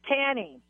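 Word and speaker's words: Tannie: Okay, it's Tanny.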